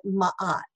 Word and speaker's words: Ma'at: Ma'at is spelled M-A, and there's a little apostrophe, A-T. So it's like Matt, but Ma'at, okay?